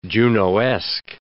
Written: Junoesque

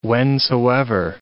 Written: whensoever

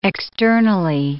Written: externally

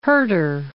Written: Herter

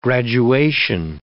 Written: graduation

